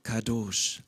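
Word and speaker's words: Kadosh (קדוש): es mündet in drei Konsonanten. K, D, Sch. Ausgesprochen Kadosh. Kadosh, das hebräische Wort für heilig,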